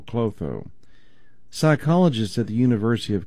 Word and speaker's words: Klotho: It's called Clotho, or Clotho. It's spelled K-L-O-T-H-O.